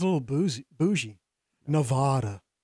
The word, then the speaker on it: Nevada: That's what I said for years until I met someone from Nevada. That's how they say it, Nevada.